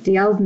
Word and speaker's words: Dalziel: And D.L.'s name is spelled Dalziel, so it's very confusing. But I used to watch the TV show, so I figured it out eventually.